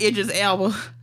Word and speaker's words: Idris Elba: We got Idris Elba.